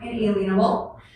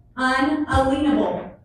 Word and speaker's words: unalienable: That word is not pronounced inalienable, as some of you may think. These rights are unalienable.